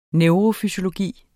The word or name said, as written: neurofysiologi